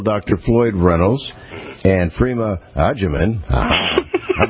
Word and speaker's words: Agyeman: Agyeman. Phonetically figured that out like we would use in Italian, agita.